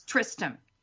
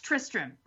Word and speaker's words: Tristram: That is Tristram. – Yep. – That's Tristram right there.